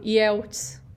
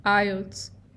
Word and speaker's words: IELTS: IELTS seria muito fácil a gente, quando lê de uma primeira vez, falar IELTS, porque é o que a gente falaria no nosso idioma, em português. Mas a pronúncia correta é IELTS.